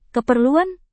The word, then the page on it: keperluan: indonesian